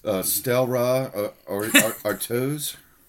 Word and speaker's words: Stella Artois: Stella Artois? – Yes.